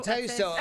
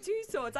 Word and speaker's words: Tussauds: Yeah, it's on its way to, I don't know how to say this, Madame Tussauds. Yeah. – Madame Tussauds. I'm sure it's said a lot. You say it different, Tussauds. I think it's my accent.